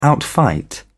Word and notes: outfight: British English

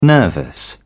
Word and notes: nervous: British English

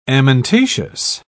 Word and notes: amentaceous: British English

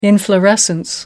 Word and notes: inflorescence: American English

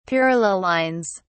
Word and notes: parallel lines: British English

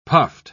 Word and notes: puffed: British English

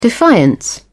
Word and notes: defiance: British English

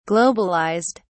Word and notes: globalized: British English

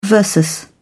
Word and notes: versus: British English

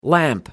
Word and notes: lamp: American English

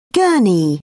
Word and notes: gurney: British English

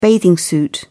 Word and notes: bathing suit: British English

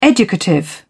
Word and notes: educative: British English

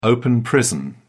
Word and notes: open prison: British English

